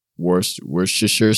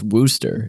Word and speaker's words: Worcestershire: I mean, there's garlic and onion and hamburger and like Worcestershire sauce. Actually, did you know how to properly say that? – Worcestershire? – No, it's Worcester. It's literally just pronounced Worcester sauce.